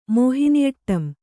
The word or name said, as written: ಮೋಹಿನಿಅಟ್ಟಂ